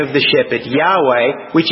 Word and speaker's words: Yahweh: In the Hebrew, in the Old Testament, it's four Hebrew letters, sometimes pronounced Yahweh.